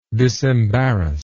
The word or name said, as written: disembarrassment